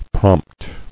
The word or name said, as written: Promt